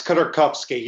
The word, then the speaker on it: Khodorkovsky: It's Khodorkovsky. You missed the K.